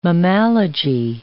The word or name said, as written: mammalogy